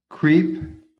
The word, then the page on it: CREEP: American English